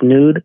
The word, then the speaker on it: Zneud: So it's it's spelled Z N E U D, pronounced nude, and you can follow us on IG as well.